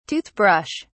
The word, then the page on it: tooth brush: British English